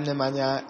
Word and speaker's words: Nemanja: I'm Nemanja, and I know maybe you can pronounce that.